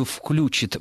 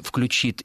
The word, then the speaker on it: включит: Да, что вот такое возможно, это все очень, извините, не конкретно, но по поводу «включит», «включит» и «звонишь-звонишь» мы говорили многократно. Да, до сих пор в русском языке единственно правильным вариантом будет ударение на окончание.